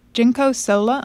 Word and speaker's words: Jinko Solar: Jinko Solar.